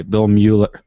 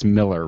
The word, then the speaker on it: Mueller: But my best memory of Bill Miller is listening to Ron, the aforementioned Ron Santo, try and pronounce his name every single day. Bill Mueller. Pat says, it's Miller, Ron. Oh, yeah, Bill Mueller. No chance.